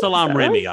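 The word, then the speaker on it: Salaam Remi: And then he turned to the producer, how do you pronounce this name? Salam Remy? – Salam Remy, I would say, yes.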